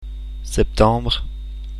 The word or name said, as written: septembre